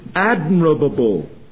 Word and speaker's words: admirable: That which is praiseworthy, or as the New International Version renders it, that which is admirable. Admirable. Can't say it. Admirable. Ah, I got it out. I always want to get in an extra syllable when I say admirable.